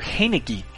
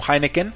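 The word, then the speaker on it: Heinicke: Heineke, que muchos lo pronuncian como Heineken, y bueno, él es un coreback que ha rebotado por toda la NFL y hasta por la AAF.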